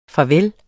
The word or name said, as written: farvel